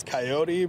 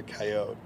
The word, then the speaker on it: coyote: Well, it's pronounced in two different ways. From where I'm from in Montana originally, it's coyote, but down here it's a coyote.